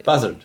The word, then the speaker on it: Buzzard: Buzzard. – Doch, Buzzard. – Buzzard. Buzzard. Buzzard. – Okay. – Und habe ich natürlich recherchiert. Es gibt mehrere Möglichkeiten. Es gibt aber auch eine Gruppe, die heißt Buzzard, Buzzard, Buzzard.